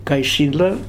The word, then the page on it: Kaishila: Futuna